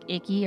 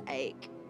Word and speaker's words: Icke: It's actually spelled I-C-K-E, which to me sounds like icky or ick. – Yes, I was like. – It's pronounced Icke. – Are you sure? – Anyway. – I feel like he was really tired of people calling him Icky, and so he was like, it's pronounced Ike.